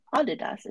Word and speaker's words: Adidas: and it was actually Adidas is how it should be pronounced.